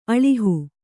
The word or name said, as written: ಆಲುಹ